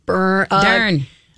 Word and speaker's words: Dern: Last name rhymes with Schmern. – Schmern. – Dern.